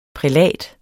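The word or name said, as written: PRÆLATS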